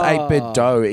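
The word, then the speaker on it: 8BitDo: I think it's pronounced 8-Bit Dough, even though it's just D-O, so it should be do.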